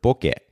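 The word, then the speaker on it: boke (ボケ): I realize that I'm technically not pronouncing the term correctly. And Anisha, I'm not sure if you realize this as well, but Boca is originally a Japanese word, boke. And I actually grew up in Japan, so I should be pronouncing it that way.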